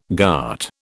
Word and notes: ghat: British English

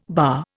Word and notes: bah: Indian

